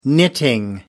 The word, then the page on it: knitting: American English